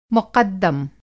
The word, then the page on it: मुक़द्दम: مقدم